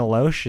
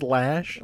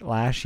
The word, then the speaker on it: Loesch: Dana Loesch, is that I think it's pronounced Lash. – Lash, yeah.